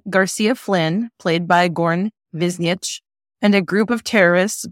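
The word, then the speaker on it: Goran Višnjić: Stars Abigail Spencer, Matt Lanter, Malcolm Barrett, Patterson Joseph, Sakina Jaffrey, Claudia Dumit, and Goran Biznich, which I just realized I've been saying his name wrong for years. So yeah, I think I always called him Biznich. – I think I was calling him Vijiznik. It's Biznich. Okay. – Biznich.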